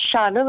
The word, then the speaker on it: Shauna: they call me Shauna, and they'll look at me and be like, how do you say your name? I'm like, it's Shauna.